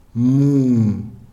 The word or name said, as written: muu